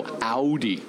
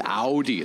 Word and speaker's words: Audi: Okay, so Audi. Yes. It's Audi, like the way Schwarzenegger would pronounce. – Yes, it is a German automobile, so you would pronounce it that way.